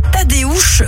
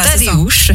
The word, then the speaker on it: Tadeusz: C'est Tadeusz Reichstein. – Exactement, j'ai bien prononcé. Oui, c'est parce que c'est à la polonaise. Donc voilà, Tadeusz. C'est ça. Tadeusz.